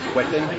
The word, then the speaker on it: Whetten: Sí, mi nombre, primer nombre es Conal, se escribe C-O-N-A-L, apellido es Quetten, W-H-E-T-T-E-N.